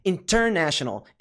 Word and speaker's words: international: Então, não é international. International. International. Não. É international. International. Então, né? É a sílaba tônica, ok?